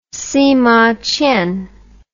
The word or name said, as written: simaqian